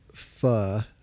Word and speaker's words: Pho: The only time I've been to Pho, I guess I'm saying that right, yeah,